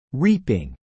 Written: reaping

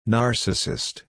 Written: Narcissist

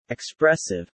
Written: expressive

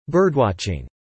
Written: birdwatching

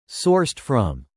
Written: sourced from

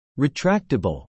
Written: retractable

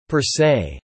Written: per se

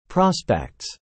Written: prospects

